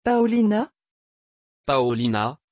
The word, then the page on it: paolina: French